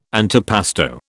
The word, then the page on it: antipasto: British English